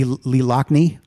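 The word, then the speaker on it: Loughnane: So we get to hear the amazing horns of Lee Lughane. Is that how you say his name? – Lee Lockney. – Lee Lockney. – Lockney. – Lockney. – I think it's Lockney.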